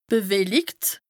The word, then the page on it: bewellikt: Bas-Rhin; Strasbourg